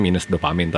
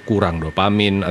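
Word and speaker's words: -Dopamin: kalau kita sendiri sih bacanya sebenarnya minus dopamin tapi sebenarnya orang-orang bisa aja membaca itu kurang dopamin atau min dopamin tapi kita sendiri sih officially menyebutnya minus dopamin selama WFH ini gimana guys silaturahmi lo enak atau malah tambah hangat nih? – Ya walaupun semua dilakukan melalui hmm.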